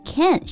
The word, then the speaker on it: Cannes: 我一说它的中文，你一定知道了啊，就是我们熟知的戛纳电影节，C A N N E S。因为是法语，所以发音呢就是 Cannes，像英文的 Cannes 这个单词。